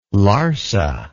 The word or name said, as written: Larsa